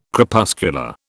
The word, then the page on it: Crepuscular: British English